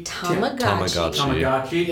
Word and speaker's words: Tamagotchi: Is it Tamagotchi? – Tamagotchi.